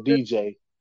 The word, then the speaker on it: DIIJAI: His name is spelled DIIJAI. So DJ.